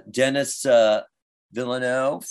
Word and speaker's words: Denis Villeneuve: Then I think the other two people on the list, even though it'll only be five, will either be Denis Villeneuve for, I think that's how you pronounce his name, yes, for Dune, yeah.